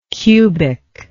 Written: cubicity

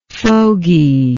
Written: fogydom

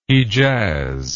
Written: Hedjaz

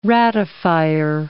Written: ratifier

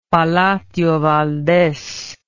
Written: palacio valdes

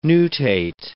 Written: nutate

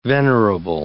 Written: venerable

